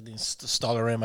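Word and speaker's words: Stalhrim: The Stalr? How do you say that? – Stalker Rim.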